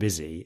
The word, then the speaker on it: busy: And so all of these ones where you've got like Susie and busy, it's USY and a letter. But one of them you pronounce Susie and one of them you pronounce Boozy. – Busy. – No, Busy. – Busy. – tear in eye and your tear address. But it's T-E-A-R.